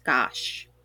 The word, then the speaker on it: Gosch: G-O-S-C-H, but it is pronounced gosh.